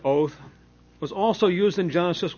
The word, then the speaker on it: 'owth: And the word sign in Hebrew is pronounced oath, O-W-T-H.